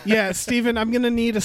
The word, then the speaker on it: Stephen: Thank you to Stephen, quote, it's pronounced Stephen, not Stefan, end quote, Simmons.